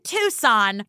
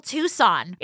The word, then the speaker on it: Tucson: Can you spell Tucson? It's sneaky. There's a silent C in there.